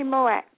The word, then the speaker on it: Moët: Most people say, oh, I want some Moët, because they think they're pronouncing it correctly, and I just wanted to make that correction there. – No, it's definitely Moët.